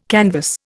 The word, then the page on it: canvass: Indian